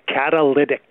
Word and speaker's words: catalytic: So what's the proper way to say it? – Catalytic. – Catalytic, yes. I apologize. Yeah. And for our listener who texted in, too, it's not a Cadillac converter.